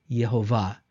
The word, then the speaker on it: Jehovah: And that's how not Jehovah, it would have been written this way, but it would have been pronounced something like Yehovah, Yehovah. And in English, we would read that as Jehovah.